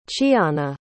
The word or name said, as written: chianna